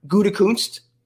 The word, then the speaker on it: Gutekunst: But also, contrarily, Peter King reported that the Packers GM, Gutekunst, say it right? – Yep.